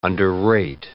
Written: underrate

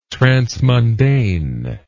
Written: transmundane

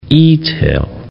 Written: e-tail

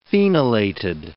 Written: phenolated

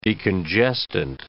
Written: decongestant

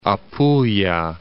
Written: Apulia

